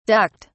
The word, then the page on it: ducked: British English